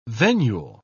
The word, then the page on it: venule: British English